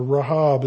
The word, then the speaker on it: Rahab: Which takes us down now in verse 31 to Rahab, or Rahab is probably the right way to say it.